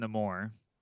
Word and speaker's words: Namor: I like, now in the now his in the MCU, his it's pronounced Namor, but.